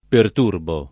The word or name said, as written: perturbare